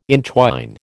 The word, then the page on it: entwine: American English